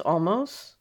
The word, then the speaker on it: Olmos: It's James Olmos.